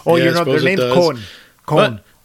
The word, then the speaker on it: Coen: Although I think it's usually pronounced Cohen, apparently. – Well, they're from Minneapolis. – It doesn't make sense. Then the N and the E should be transposed.